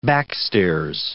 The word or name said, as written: backstairs